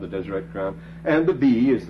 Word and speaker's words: Deseret: The word holy here is written very phonetically, Deshereo. It's Deseret. It's the word for holy, remember, set apart, sanctified, Deseret.